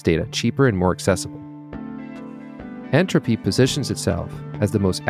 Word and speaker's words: Ntropy: My guest today is Naré Vardanyan, co-founder and CEO of Ntropy, spelled with the capital letter N, a play on the word entropy, used in thermodynamics modeling.